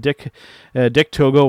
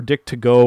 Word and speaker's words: Dick Togo: We had Sonata defeating Evil with Dick Togo, or Dick Togo, or however you want to kind of pronounce that at ringside.